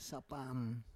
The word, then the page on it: sapam: Roon